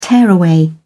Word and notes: tearaway: British English

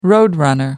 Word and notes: roadrunner: American English

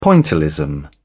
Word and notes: pointillism: British English

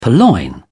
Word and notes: purloin: British English

